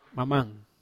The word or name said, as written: mamang